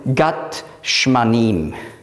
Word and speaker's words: Gethsemane: Auf Hebräisch würde man schreiben, Gat Shmanim. Auf Griechisch gibt es kein Sch.